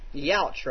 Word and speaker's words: Yauch: His handle is Yauch or Yuck or something like that. – Yauch. – Y-A-U-C-H. – Yauch, right? – Yauch. – Is he named after Adam Yauch?